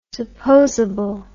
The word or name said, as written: supposable